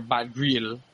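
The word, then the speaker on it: Batgerel: But the next one that I have, the first one that I have to really dive into, is Dana Batgrili, or Batgril, let me say that right. Dana Batgril versus Kevin Natividad.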